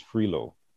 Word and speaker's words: Frilot: But one of the vice presidents of UL or USL, Dean Patricia Cottenham, I think her maiden name is Frilo, but it's spelled F-R-I-L-O-T.